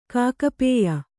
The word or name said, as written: ಕಾಕಪೇಯ